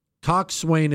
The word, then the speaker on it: coxswain: Coxswain is not pronounced the way it's written. It's pronounced coxswain with the emphasis on the first syllable.